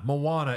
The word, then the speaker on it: Moana: No, oh, the Mona? Movie title is... Moana. – Moana. – But she said... – Moana. Moana is how you pronounce it, but his name in it is Maui.